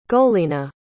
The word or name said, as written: golena